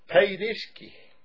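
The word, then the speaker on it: paidiski (παιδίσκη): And the word there is, in the Greek, is 38:14, and the Strong's, and it's pronounced the way I got it, paidiski, like, almost like she was paid to do it.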